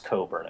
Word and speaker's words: Cockburn: It's actually pronounced Coburn, I think. By Alexander Coburn.